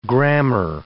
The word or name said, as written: grammar